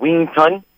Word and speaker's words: Wing Chun: It's called Wing Chun.